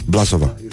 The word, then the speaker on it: Blasova: Nu e Blasova. Da, Blasova. Da. da. N-am fost, dar știu că așa se pronunță.